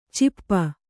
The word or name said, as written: ಛಪ್ಪೆ